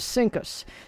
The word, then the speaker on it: CINCUS: But when they pronounced it, it was CINCUS.